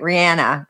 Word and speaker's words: Rihanna: But Samantha Cashin said, Rihanna, how do I say it? Rihanna. – Yeah, you're good.